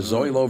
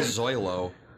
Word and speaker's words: Zoilo: And I do remember Zoilo